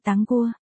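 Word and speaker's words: táng cua (糖果): Kẹo ngọt như ở văn án đã đề cập, kẹo ngọt tiếng Trung là tang cua, phát âm giống với Đường Quả. Tang cua, đều đọc là táng cua, ở đây anh Thẩm đang trêu chọc Đường Quả.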